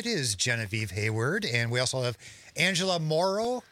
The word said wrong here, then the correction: Morano: Did I say that right? – Morano. – Morano, I'm sorry.